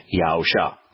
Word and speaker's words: Yahusha: I'm fairly convinced that Yahweh knows how to spell his name, and he spelled it so that it is pronounced Yahusha.